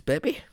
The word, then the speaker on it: Bibbe: So it's spelled B-I-B-B-E. – That's Bibi. You're right.